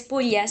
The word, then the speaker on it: Puglia: De, de Puria, ¿puede ser? Sí, esto es Puglia.